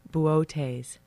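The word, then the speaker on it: Boötes: Bootes.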